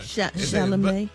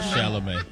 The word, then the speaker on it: Chalamet: Chalamet. – You was close, though. You say. – You ever thought about going back? You ever thought about going back to kindergarten? – It's like Chevrolet. Oh, not Chevrolet.